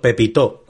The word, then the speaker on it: Pepito: sí, se llama gato Pepito, Pepito, con acento en la O final.